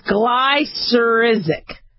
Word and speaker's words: glycyrrhizic: It's G-L-Y, so there's your gly, and then it's C-Y-R-R-H. So, cyrrhizic, I-Z-I-C. So, I'm saying it's glycyrrhizic acid.